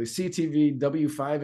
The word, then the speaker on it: CTV W5: And you were featured on a I'm going to try to say this correctly, CTV W5 investigation.